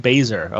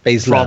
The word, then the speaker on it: Baszler: Shayna Baszler. Okay. – Baszler.